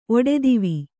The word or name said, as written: ಒಡೆದಿವಿ